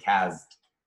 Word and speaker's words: CAZT: He'd always be like, you're the only person that pronounces the Z. And it's like, no, I'm now with Seth. And we are the only two people in Los Angeles that pronounce the Z in CAZT.